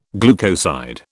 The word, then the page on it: glucoside: British English